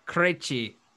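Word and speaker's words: Krejčí: Vit Krejci. – Okay. Krejci. It's Krejci. – Oh!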